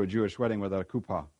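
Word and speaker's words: kupa (חופה): H-U-P-P-A, or in the Hebrew, it's actually a C in front of it, pronounced kupa, kupa.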